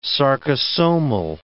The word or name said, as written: sarcosomal